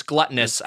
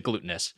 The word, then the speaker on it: gluttonous: And I also, it's gluttonous. I pronounce it glutinous.